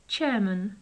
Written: chairman

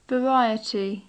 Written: variety

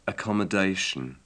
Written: accommodation

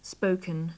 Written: spoken

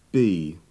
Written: bee

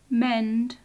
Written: mend

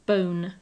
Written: bone

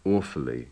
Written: awfully

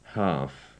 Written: half

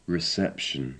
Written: reception

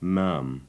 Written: maam

